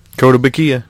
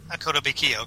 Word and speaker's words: Kotobukiya: And I always say this wrong. Kotobuki, is that right? – Kotobukiya, yeah. – Kotobukiya, okay. Has a Wrecker statue coming, and it was listed as March 2022.